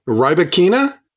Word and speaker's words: Rybakina: How do you say the girl's name who won? Rybakina? – Yes. Wow, well done.